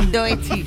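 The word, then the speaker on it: Doechii: Y a ver, ¿esto cómo se pronuncia? – Doechi.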